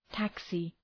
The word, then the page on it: taxi: ‘tæksı